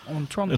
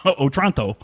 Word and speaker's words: Otranto: Otranto. – It's like Tonto, but with a Otron at the beginning. Otranto.